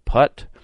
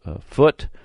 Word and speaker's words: Phut: Now you can some have pronounced that Put, some Foot, some Phut, and so I don't know how we're going to probably leave it at Phut and go from there.